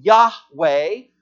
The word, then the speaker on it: Yahweh: That word Lord goes back to the Old Testament of the personal name of God, which is YHWH, the two ways to pronounce it. If you've heard the name Yahweh, or if you've heard the name Jehovah, that the name of Jesus that will confess that Jesus is Yahweh.